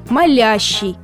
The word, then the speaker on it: молящий: Ошибка возникает от того, что в глаголе «молит» ударение практически всегда падает на первый слог. Он молит, они молят, ты молишь, мы молим и так далее. Однако в разных частях речи далеко не всегда совпадают ударные слоги. И как бы ни было великое искушение поставить ударение по аналогии с глаголом «молит», в причастии ударной будет «я», «молящий». Например, «молящий Бога», «посмотреть на друга молящим взглядом», «увидеть молящегося человека», «враг молящий о пощаде».